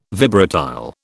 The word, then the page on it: vibratile: British English